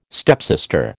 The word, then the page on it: stepsister: American English